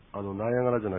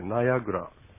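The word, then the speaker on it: Niagara: 私はナイアガラの滝を見たい。私はナイアガラの滝を見たい。まあ、英語では、ナイアガラじゃなくて、ナイアグラというふうに言うそうですけども、まあ、これは日本語で発音します。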